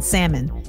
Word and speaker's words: salmon: It's like y'all who are pronouncing the L in salmon. Leave it alone. Salmon, that's not it, y'all.